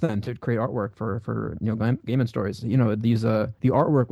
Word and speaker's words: Neil Gaiman: And he worked with Neil Gaiman.